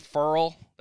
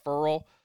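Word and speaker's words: Ferrell: Cleveland Furl, that's how you say it, Furl, is third.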